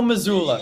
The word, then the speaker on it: Mazzulla: You said, I think, Mazzulla or something like that. – Joe Mazzulla. Yeah, like, there you go. – You flipped, like, vowels there.